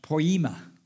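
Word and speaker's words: poema (ποίημα): Uh, the Greek word is pronounced poema, and we get our word poem from poema.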